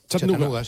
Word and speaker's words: Chattanooga: Chattanooga, sí.